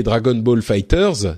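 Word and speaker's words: Dragon Ball FighterZ: Et en parlant de succès de ce début d'année, il faut bien sûr évoquer Dragon Ball Fighters, c'est la prononciation officielle, hein, Dragon Ball Fighters, qu'on avait déjà évoqué il y a quelques épisodes, mais qui là donc est évidemment sorti.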